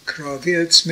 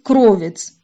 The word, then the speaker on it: кровец: На записи и в других вариантах, с которыми я знакома, она произносит «кравец», а должна произноситься «кровец», что следует из ответа Рэбе, который расшифровывает это слово как аббревиатуру фразы «Коль рино вейшуа беоле цадиким» – «Глаз радости и избавления в шатрах праведников».